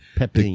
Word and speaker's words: Pepin: I am still smoking on my Don Garcia, Don, how do you say it? Don Pepin Garcia, Pepin.